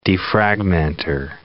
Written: defragmenter

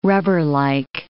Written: rubberlike

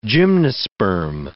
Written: gymnosperm

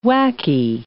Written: wacky

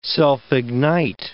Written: self-ignite

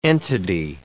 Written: entity

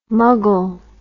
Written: muggle

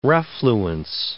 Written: refluence

